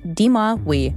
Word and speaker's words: Dis-Moi Oui: The episode is titled Dis-Moi Oui.